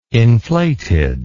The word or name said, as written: inflated